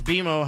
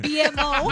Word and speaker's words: BMO: It's pronounced BMO. Hello. – Right. – Oh, BMO. My bad. Not my bicep. BMO.